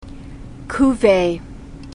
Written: cuvee

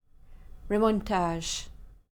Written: remontage

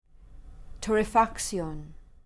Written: torrefaction

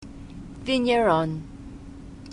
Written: vigneron

